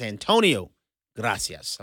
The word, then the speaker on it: Antonio Gracias: I mispronounced Mr. Gracias' name. It's Antonio Gracias.